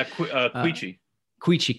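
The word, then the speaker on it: Quechee: You know, as you say, Keechee, is that how you say it? – Yeah. Queechee. – Queechee, yeah.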